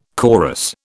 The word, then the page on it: chorus: British English